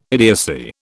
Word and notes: idiocy: British English

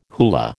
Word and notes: hula: American English

